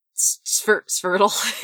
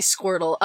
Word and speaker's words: Svirtle: So I'm thinking a really quick scene between Tony, Tornado, and Skeets where we realize that Svirtle I keep wanting to say Squirtle is missing. – Yeah, it's funny. It is funnier when you all mispronounce it like that.